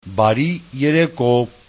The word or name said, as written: bariereko